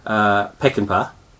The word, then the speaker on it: Peckinpah: So my first choice by the studio was Peckinpah. Is that how you pronounce his name? – Yep. – Sam Peckinpah.